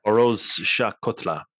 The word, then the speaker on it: Feroz Shah Kotla: Now you're going to have to remind me how to say this. Was it the Shah Kolta? – Feroz Shah Kotla. – That's it.